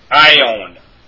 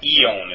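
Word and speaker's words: ion (αἰών): An example of that would be ion in the Koine Greek scriptures. Ion, meaning age. It's not eon, it's ion.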